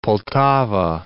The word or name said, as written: Poltava